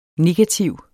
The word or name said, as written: negativ